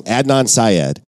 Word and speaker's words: Adnan Syed: Adnan Syed.